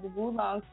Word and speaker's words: oolong: Wulong tea, it's spelled O-O-L-O-N-G.